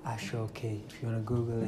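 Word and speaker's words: aso oke: A-S-O space O-K-E, ashoke. In the Yoruba culture, S is pronounced in this case as in S-H. So there's an accent on the S. So it's ashoke. So A-S-O instead of aso is ashoke.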